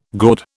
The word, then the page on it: gaud: British English